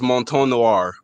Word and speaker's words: Mouton Noir: But it's pronounced Mouton Noir, almost like mountain.